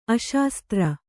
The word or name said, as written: ಅಶಾಸ್ತ್ರ